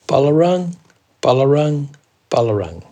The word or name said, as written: balarang